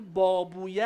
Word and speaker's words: بابویه: میگیم فلانی بابویه است، یعنی شبیه مرحوم باباست، ابن بابویه اینجا هست، باب وی. بعد تو زبان عربی این اویه تلفظ میشه وی.